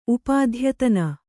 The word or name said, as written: ಉಪಾಧ್ಯತನ